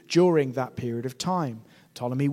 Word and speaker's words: Ptolemy: And in the south, we have a man called Ptolemy, with a silent P at the front.